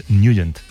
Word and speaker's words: Nugent: Siempre lo hemos llamado en el barrio Tetnagent o Tetnagent. – Claro, yo era de Tetnagent. – Pero la pronunciación correcta es Nugent. – Te fuiste a Washington y descubriste que era Nugent.